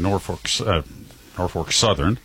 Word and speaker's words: Norfolk Southern: Union Pacific in Norfolk Southern.